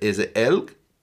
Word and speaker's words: Elg: How would you say it anyway? Is it Elg? – Elg.